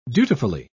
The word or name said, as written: dutifully